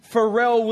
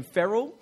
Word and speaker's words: Pharrell: Pharrell Williams is his name. Hey, say it right. I got corrected this morning when I said his name. I called him Ferrell.